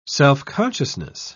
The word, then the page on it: self-consciousness: British English